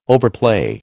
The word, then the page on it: overplay: American English